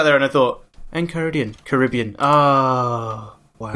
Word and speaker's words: Caribbean: Except you've got to say it like an American, so it's Caribbean.